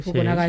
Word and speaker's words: Fukunaga: Fukunaga está.